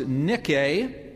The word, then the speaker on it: nike: The Greek noun is pronounced nike.